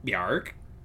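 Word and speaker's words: Björk: No, that's how you have to say it. That's how it's pronounced in the traditional Icelandic. Bjork.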